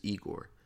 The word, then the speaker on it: Igor: this is Igor. Pronounce E-Gore.